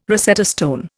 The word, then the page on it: Rosetta stone: Indian